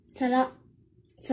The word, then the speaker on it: 皿: 今天我们要来学习的日语汉字呢是“ 器皿” 的“ 皿”，但是不知道为什么这个字呢在日语当中居然没有音读的发音，训只有训读，训读的时候读作 “ さら”，“ さら”。